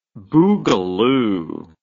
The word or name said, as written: boogaloo